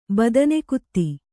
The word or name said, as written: ಬದನೆಕುತ್ತಿ